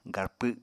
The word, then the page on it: garpw: apatani